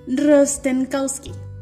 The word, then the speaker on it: Rostenkowski: Aquí está. Rostenkowski. – Rostenkowski. Rostenkowski. Ok.